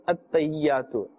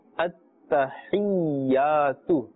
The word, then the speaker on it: التحيات: not التيات التيات is incorrect التحيات